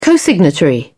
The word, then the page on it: cosignatory: British English